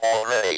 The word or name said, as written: already